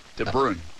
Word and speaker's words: De Bruyne: Did I say that right? – Belgium? – No. Bruyne. De Bruyne.